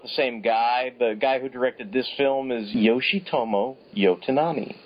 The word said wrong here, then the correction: Yonetani: Okay, I think I'm saying that right. Yonetani. Okay, Yonetani.